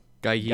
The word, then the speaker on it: Gueye: Im Hinspiel Idrissa Gueye, wie spricht man den aus? Gueye? Äh, Gaye. Gaye.